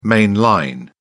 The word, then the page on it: main line: British English